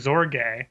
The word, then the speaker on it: Sorge: But in his encyclical, Mit Brennender Sorge, It's spelled like Sorge, but pronounced like with a Z.